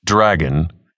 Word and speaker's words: DRAG.N: The UHI's disbursement of regular antibodies for a greener nation, DRAG.N for short, pronounced Dragon, had been the president's platform for re-election.